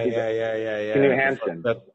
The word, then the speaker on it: Knut Hamsun: Knut Hamsun.